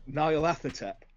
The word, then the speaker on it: Nyarlathotep: How do you pronounce it? – Nyarlathotep. – Okay. – Yeah. I'm different again. – Okay. And yours is probably the correct way because the people who know more than me pronounce it that way.